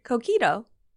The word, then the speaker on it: Coquito: Cojito. – No, you said it the second. Coquito. – Cojito.